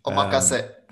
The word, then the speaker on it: omakase: But taking the technology that they developed for their omakase berry, did I say that wrong? – Omakase.